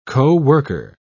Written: co-worker

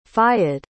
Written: fayed

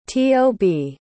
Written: tob

